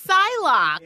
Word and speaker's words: Psylocke: It's Psylocke.